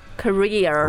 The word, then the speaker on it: career: in England, they're pretty similar In America, they're very different、I have a career in Korea So in America, we make that R sound at the end, career Career.